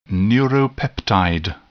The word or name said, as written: neuropeptide